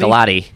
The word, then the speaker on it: Gulati: How do you pronounce his last name? Gulati? – Gulati, yep.